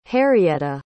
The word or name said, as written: harrietta